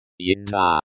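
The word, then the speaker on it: еда: There are other words for food in Russian, but yeda. Yeda is the most general and widely used. Pronunciation. Yeda. Yeda. The e is pronounced like the e in bed.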